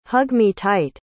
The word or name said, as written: hug-me-tight